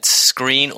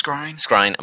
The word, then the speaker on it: Skrein: So my piece of news for the week is that Ed Skrein, or Skrine, we're still not quite sure how to pronounce it. – I think it's Skrine. – I'm going to go with Skrine.